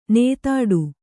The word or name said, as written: ನೇತಾಡು